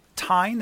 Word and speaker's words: Tyne: Ladies and gentlemen, our guest, James Tooley, professor of education policy at the University of Newcastle upon Tyne. Is that how you pronounce it, professor? – It is. It's a river, the Tyne River.